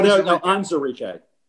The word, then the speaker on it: Onwuzurike: Onwuzurike, right? – No, Onwuzurike.